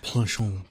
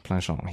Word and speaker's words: Planchon: Planchon. – Planchon.